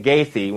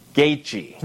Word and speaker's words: Gaethje: It's just like I pronounced on UFC the other day, Justin Gaethje, when it's Justin Gaethje.